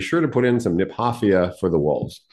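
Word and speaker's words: Kniphofia: By the way, I pronounced it Nepophia my whole life until the emeritus superintendent of the Arboretum told me it was named after a guy named Niphoff. So it should be pronounced Niphoffia.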